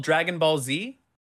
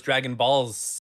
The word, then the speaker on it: Dragon Ball Z: This is called Dragon Ball Z? – Yeah. – I've been saying this wrong. I just thought it was Dragon Balls.